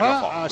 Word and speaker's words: Ben Arfa: Nos lo ha dicho él mismo, ¿eh? Ben Arfa, Ben Arfa, así Ben Arfa, que hay que pronunciarlo en andaluz, Ben Arfa.